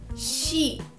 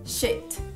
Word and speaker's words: sheet: Mais uma vez, cheat, sheet, cheat, sheet. Então, mais uma vez, perceba a pronúncia entre sheet e shit.